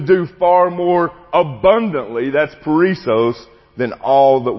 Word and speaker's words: perissos: The word abundant in Greek is the word perissos. It's spelled P-E-R-I-S-S-O-S. Perissos.